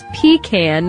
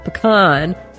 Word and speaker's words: pecan: Uh-oh, she says, pronounced by us as pecan, not pecan.